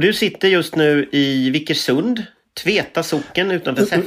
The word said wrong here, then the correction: Vickersrud: Gud, mm, Eller uttalar jag det fel nu? – Vick, Vickersrud.